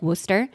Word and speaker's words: Worcester: like Worcester, which has a bunch of letters that aren't pronounced, and that's fine.